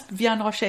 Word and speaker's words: Vianne Roche: That's, Vianne Roche is fine.